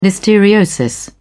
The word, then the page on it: listeriosis: British English